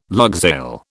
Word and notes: lugsail: British English

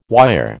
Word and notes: wire: American English

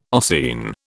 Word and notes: ossein: British English

